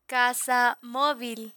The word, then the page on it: casa movil: spanish